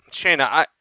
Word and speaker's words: Shayna: And Shayna, is how you pronounce it? – Shayna.